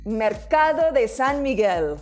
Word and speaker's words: Mercado de San Miguel: Mercado de San Miguel. Have I done well? – Mercado de San Miguel.